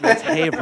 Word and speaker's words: Haverhill: And he was like, oh, how do you say the name of the town real quick? And I was like, Haverhill. And he's like, Haverhill. Like, no, it's Haverhill. I was like, Haverhill?